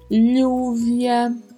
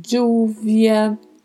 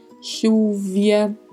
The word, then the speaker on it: lluvia: O duplo L, que pode ter sons diferentes a depender das regiões, como por exemplo, um som de LH, um som de D com J, ou até um leve som de X. E aí pronunciaria-se lluvia, lluvia, chuvia. São maneiras de pronunciar. E o V que tem um leve som de B.